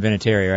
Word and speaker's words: Vinatieri: And Vinatieri, right? – Vinatieri, however, how do you say it? – Vinatieri. – Vinatieri? – That's it. That's how I say it.